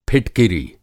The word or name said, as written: फिटकिरी